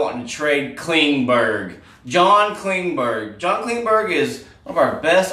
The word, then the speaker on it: Klingberg: It's not Klingberg they say, it's Klingsberg. Klingsberg they want to trade, right? Klingsberg's, no one can get his name right.